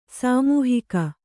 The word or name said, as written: ಸಮ್ಮೋಹಕ